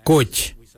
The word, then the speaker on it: Goodj: Karli is spelled G-I-I-L-Y-I, karli. For the traditional stone axe, we say koch, koch. Koch is spelled G-O-O-D-J, koch.